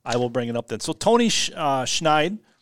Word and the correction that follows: Scheid: Scheid. – Just Scheid. No S. No, the C is silent.